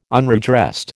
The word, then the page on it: unredressed: American English